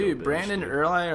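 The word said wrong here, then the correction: Urie: or how you pronounce his last name? Yuri.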